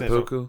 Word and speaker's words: seppuku: So it's called, in Japan, it's called, and I'm probably pronouncing seppuku.